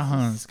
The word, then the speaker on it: Reims: Hans escreve R-E-I-M-S, porque às vezes é meio complicado, né? Assim, só falam Hans, como é pronúncia mais francesa. Hans escreve R-E-I-M-S.